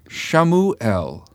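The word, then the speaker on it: Shmuel: So Samuel, pronounced in Hebrew, is what? – Shamuel. – Shamuel. El meaning God. – El meaning God. – And Shamu is heard.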